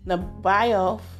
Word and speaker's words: Nebaioth: Verse 7, "All the flocks of Kedar shall be gathered together unto thee, the rams of Nebaioth, yeah, the I is silent, shall minister unto thee.